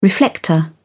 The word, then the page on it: reflector: British English